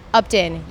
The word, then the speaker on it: Upton: Well, because so many people spell my name wrong, even whenever they see it, when they Google me, is spelled correctly, C-A-I-T-E, Katie Upton, U-P-T-O-N.